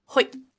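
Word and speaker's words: Hoid: Because Hoyd is the one is that how they say it in the audiobook, Hoyd? – Hoyd. – Okay. It seemed like that was the right way to say it.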